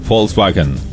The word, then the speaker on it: Volkswagen: Compañera, se pronuncia Volkswagen.